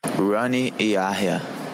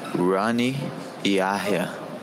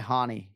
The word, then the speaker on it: Rani Yahya: Rani Yahya. Rani Yahya. – He does not go by Hani. He has Americanized it. He says Rani Yahya.